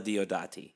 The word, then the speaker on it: Diodati: This episode, we're going to talk about the haunting of Villa Diodati, or as it's pronounced correctly, the haunting of Villa Diodati.